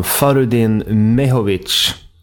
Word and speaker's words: Farudin Mehovic: Det här handlar om Farudin Mehovic, om jag får gissa på uttalet. – Mycket fint uttalare, ja.